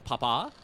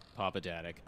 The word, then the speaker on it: Papadatic: Don't say it like that. I don't Papa, like that. Nope. – All right. Papa Daddick, are you... – Yeah, that's right.